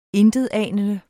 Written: INTETANENDE

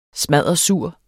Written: smaddersur

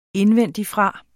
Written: indvendigfra

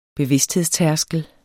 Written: bevidsthedstærskel

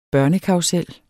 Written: børnekarrusel

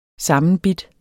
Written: sammenbidt

